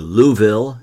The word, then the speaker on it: Louisville: The Nationals this year are back in Louisville, and or Louisville, as they say down there. I believe that's how they say it down there.